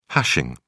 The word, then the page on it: hashing: British English